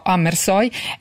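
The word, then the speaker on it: Hammershøi: Gli interni domestici, per il quale Amersøe, e la pronuncia rassicuro chi ci ascolta, io l'ho verificata con la curatrice Sandra Gianfreda e quindi dirò Amersøe,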